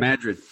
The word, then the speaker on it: Madrid: The breakthrough there was that in Madrid, is that how you say it? Is that, am I saying that right? – Madrid, right. – Madrid.